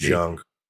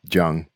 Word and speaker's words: Jung: Carl Jung have written about the. – I believe it's pronounced Jung. – Yeah, Jung.